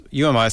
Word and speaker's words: You Am I: UMI, pronounced UMI, spelt Y-O-U-A-M-I, with Tim Rogers on vocals and that classic track there at the end called Embarrassed.